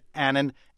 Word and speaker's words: Annan: His spokesman said Annan as in canon.